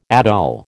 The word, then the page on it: atoll: American English